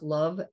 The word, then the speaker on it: Lauv: An artist named Lauv, L-A-U-V. I think it's pronounced Love, L-A-U-V.